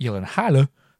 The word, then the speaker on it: Gyllenhaal: It's Nordic. So it's in that whatever country it hails from, it's pronounced Gyllenhaal. – But Jake Gyllenhaal doesn't pronounce it that way.